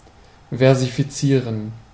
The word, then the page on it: versifizieren: /ˌfɛʁzifiˈtsiːʁən/; [ˌfɛɐ̯zifiˈtsiːɐ̯n]; German